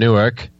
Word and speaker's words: Newark: So, over across the pond, we got Nutters from Newark, Mr. Chris and Kev.